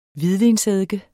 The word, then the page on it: hvidvinseddike: [ ˈviðvins- ]